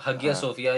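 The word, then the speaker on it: Hagia Sophia: वैसे मैं आया सोफिया उसको original नाम था, और हागिया सोफिया या हाजिया सोफिया हम कहते हैं.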